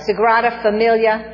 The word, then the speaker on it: Sagrada Familia: Yes, Sagrada, is that how you say it? Yeah, Sagrada Familia in Barcelona, Spain.